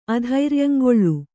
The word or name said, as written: ಅಧೈರ್ಯಂಗೊಳ್